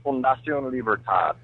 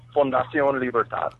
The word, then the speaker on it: Fundación Libertad: That's Fundación Libertad, which is basically Liberty Foundation in English, but it just sounds so much cooler in Spanish.